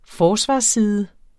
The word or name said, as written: forsvarsside